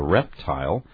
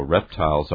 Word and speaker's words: reptile: 这个发音你一直念这个大哥你是念的是这个reptile 但是我也有时候听到不少美国人他不念reptile 他要念怎么念 reptile We have two pronunciations here, reptile or reptile. Okay, the interesting thing here, I think, is the Chinese dragon, the model for this dragon, seems to have been a reptile, so reptiles are also important in Chinese imagery.